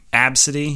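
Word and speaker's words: Abcde: Yeah, it's pronounced Absidy. Absidy, yeah.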